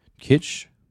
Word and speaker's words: kitsch: I'm going to pronounce this wrong. Kitsch? – Sounds right.